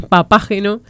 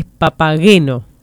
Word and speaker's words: Papageno: Bueno, uno es un mensaje que te corrige la pronunciación, que no es papágeno. – Ok. – Que dice que el personaje de la ópera de Mozart en realidad es Papageno.